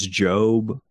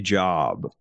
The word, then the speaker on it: Job: Last thing I'll say about it, you remember Mary Langston, there's a book in the Bible, I think it's Job. Is that right? – Mm-hmm. – Is that how you pronounce it? – That's right. – I used to say Job, but I think my parents corrected me when I was young.